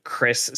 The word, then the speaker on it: Cris: I do want to say I looked up because even though my name is Christian, I go by Chris spelled C-R-I-S.